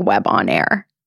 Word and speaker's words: Webonair: Webonair. – So it's actually spelled like Web on Air. – Yes. – Yeah. – Yes. – Webonair. – Yes. Like debonair.